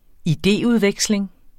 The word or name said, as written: idéudveksling